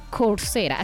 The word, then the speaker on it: Coursera: Coursera.